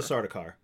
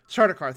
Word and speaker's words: Sardaukar: I can never pronounce their name, the soldiers of the Emperor, the Sardaukar. – Sardaukar, thank you.